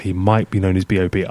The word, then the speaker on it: B.o.B: What do you know about the hip-hop star Bob? – Bob. What I do know about Bob is that I think it should be pronounced B-O-B. – B-O-B.